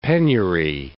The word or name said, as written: penury